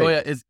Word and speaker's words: Gioia: Joya is spelled, you wouldn't think G-I-O-I-A. It's pronounced. G-I-O-I-A. Yeah, G-I-O-I-A.